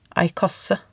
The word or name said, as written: ei kasse